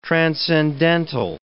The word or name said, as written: transcendental